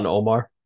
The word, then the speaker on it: Ilhan Omar: And it's interesting, of course, you see, Ilhan Omar. Am I saying that right? – Ilhan Omar.